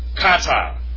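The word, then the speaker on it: kata (κατά): Kata, or kata, actually. We have dia, and we have kata. That's how it's correctly pronounced. Kata.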